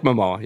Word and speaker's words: Momoa: It's Jason Momoa, right? That's how you say it? – I like Momoa, yeah.